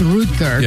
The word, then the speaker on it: Rutger: Everybody said Rutger, but I believe in German it's Rutger.